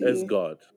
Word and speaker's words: Sgawd: I don't know how to pronounce it. S-G-A-W-D. – S-God. – S-God? S-God, yeah.